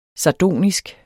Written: sardonisk

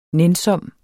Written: nænsom

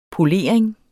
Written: polering

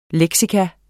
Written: leksikon

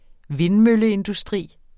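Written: VINDMØLLEINDUSTRI

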